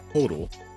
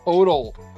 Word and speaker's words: Poeltl: So, they could not get Jakob Poeltl. However you say his name. – It's Poeltl. – Poeltl.